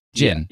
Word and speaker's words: Djinn: mischief. Djinn. Yeah. – Yeah. Like, like genie.